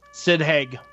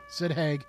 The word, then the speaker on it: Sid Haig: Sid Haig. – Sid Haig.